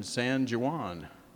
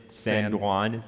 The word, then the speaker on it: San Juan: One in San Jose and one in San Juan. – What? – You don't pronounce the J's in Spanish words.